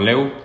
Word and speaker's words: Lew: La página en Instagram es Rocket Sales, se escribe R-O-K-K-E-T-S-A-L-E-S. E eh, a mí también pueden seguir, Eduardo Leu, todo corrido, Eduardo, mi apellido L-E-W.